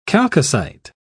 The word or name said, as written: chalcocite